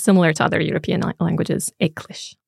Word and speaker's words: eaglais: Icklish. – Icklish. Can you spell it for me? – E-A-G-L-A-I-S.